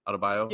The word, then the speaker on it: Adebayo: Adebayo? – Yeah.